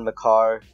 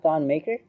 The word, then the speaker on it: Maker: Makar. I think that's how you pronounce his real name. – Really? – Yeah. – Oh, it's not Thon Maker? – Nope.